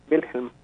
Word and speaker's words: Wilhelm: Wilhelm.